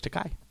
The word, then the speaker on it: Takei: And George Takei. – It's pronounced Takei.